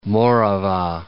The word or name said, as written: Morava